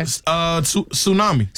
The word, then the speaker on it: tsunami: This has a silent letter in it. – Okay. – Tsunami.